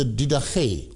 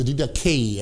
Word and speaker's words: Didache: Now, the next source is the Didache, or the Didache, as the Americans would pronounce it.